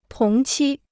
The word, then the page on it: 同期: tóng qī